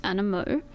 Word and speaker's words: Animaux: It's A-N-I-M-A-U-X, pronounced Animo.